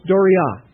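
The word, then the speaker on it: dorea: The second term I want to acquaint you with is not as frequently used, but it's fairly frequent, and it's doria. Doria. Not all of you are taking notes, but if you want to write that down, that's D-O-R-E-A with the accent on the last letter.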